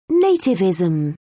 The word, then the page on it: nativism: British English